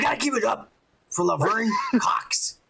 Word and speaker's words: Laverne: Who would have thought that this is Laverna Cox, you know, straight out from the orange. – Laverne.